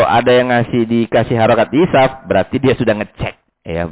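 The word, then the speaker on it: yisaf (يساف): Sehingga bacanya yisaf. Nah, ya. Ini kalau kita nggak lihat langsung ke kamusnya atau ke tarajim ruwat, bacanya salah kita mesti. Anda lihat banyak di nuskah-nuskah itu tulisannya yasaf ya.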